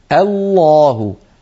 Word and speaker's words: الله: لابد من أن ننطق الهمزة مرققة ثم نفخم اللام برشاقة هكذا، الله الله، ولا نقول الله الله، الهمزة لا تفخم ولا تفخمها العرب أبدا.